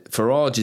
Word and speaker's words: Farage: And David said, Farage is a Norman French surname, hence the pronunciation.